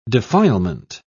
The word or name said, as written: defilement